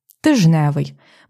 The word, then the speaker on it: тижневий: «Тижневий». Ми навіть так пишемо без букви «д».